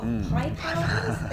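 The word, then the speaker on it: Piepowder: Can you tell me about the Court of Pipehouses? Is that what it is? – Yeah, that is actually how it's pronounced.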